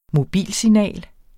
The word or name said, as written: mobilsignal